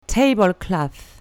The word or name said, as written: tablecloth